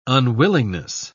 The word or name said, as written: unwillingness